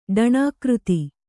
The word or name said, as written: ಡಣಾಕೃತಿ